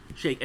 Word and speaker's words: Shayk: Shayk.